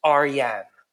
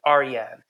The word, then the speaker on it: Arian: My Persian name is Arian. That's how you say it, Arian.